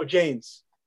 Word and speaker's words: Jains: Am I pronouncing that right? Jains? – Oh, Jains.